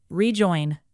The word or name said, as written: rejoin